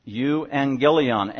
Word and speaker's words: euangelion: E-U, like an eulogy. E-U means good. A eulogy is when you say good words about somebody. Euangelion, angelion, spelled like angel, angelion.